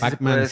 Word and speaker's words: Pac-Man: Y la N no tiene que decir Pac-Man, no. – No, la N Pac-Man sí se pronuncia ahí al final.